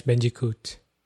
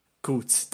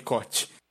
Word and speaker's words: Bandicoot: Crash Bandicoot. Coot, tá. É, eu sempre falei Cot.